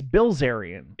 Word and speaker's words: Bilzerian: I don't often keep track of what Dan Blazarian's up to. – Oh. – I think I even say his name wrong. It's right. It's Bilzerian. – Is it? – It's not Blizzarian.